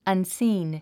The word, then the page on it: unseen: ʌnsíːn; アンスィーン